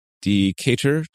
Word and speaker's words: Decatur: at the Arches Cycling Group in Decatur, Georgia.